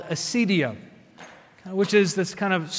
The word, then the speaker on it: acedia: This reality called acedia, well, it's spelled like the word ACE and then think like DIA, Denver International Airport, ACEDIA, acedia.